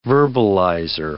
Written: verbalizer

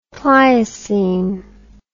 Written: pleiocene

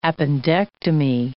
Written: appendectomy

